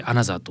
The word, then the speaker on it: ANOTR: さて、オランダはアムステルダム出身の二人組デュオアナザー、 ANOTR と書いてアナザーと読みます。